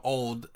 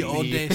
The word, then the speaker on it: olde: Because I know back in the day, they would have E's, and they would be silent. Like, the old shop, S-H-O-P-P-E. I just assumed it was silent. – It was also old, O-L-D-E. – Yeah, right. She pronounced the E.